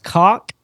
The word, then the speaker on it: Cocke: His name is Norman Atwater, and I'm assuming it's pronounced cock. It's spelled C-O-C-K-E, which Cock.